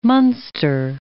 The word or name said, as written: Muenster